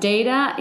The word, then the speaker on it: Deida: It's David Data. Data is spelled as D-E-I-D-A.